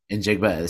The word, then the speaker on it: Njigba: Njigba.